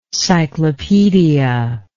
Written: cyclopedia

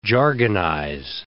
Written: jargonize

